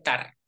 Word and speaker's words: estar: Y el verbo estar, normalmente se pronuncia, se quita el es, se dice tar solamente.